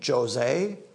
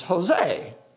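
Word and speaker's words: José: If you see a J, you pronounce it as an H. You don't say, hey, Jose. It's Jose. Tom told me today that he met a yard guy whose name is Jesus.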